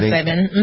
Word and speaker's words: psilocybin: Yeah, psilocybin.